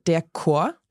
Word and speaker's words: Chor: There are some words where you pronounce it as a K. Mm-hmm. So, for example, der Chor.